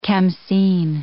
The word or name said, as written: khamsin